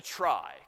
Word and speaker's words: trie: So this we'll call is a try. Kind of a stupid name. It comes from retrieval, and the word is spelled try, T-R-I-E, because of course retrieval sounds like try.